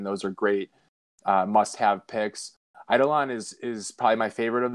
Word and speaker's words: Eidolon: But we've got Eidolon. Did I say it right that time? Hopefully. – You got it, dude.